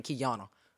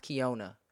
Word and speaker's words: Keyona: Like you can, I already know I'm gonna see Keyona at such and such, and that's how people say my name, Keyona. But my name is pronounced Keyona.